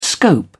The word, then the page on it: scope: British English